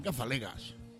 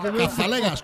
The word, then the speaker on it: Cazalegas: ¿En Casallegas? Serán Cazalegas. – Sí, pero yo lo pronuncio así. – Claro. – No, es que para entender a Dani hay que saber inglés. – Es que es la Mariano. Es un pijo. – Casaliguis. Cazalegas, coño.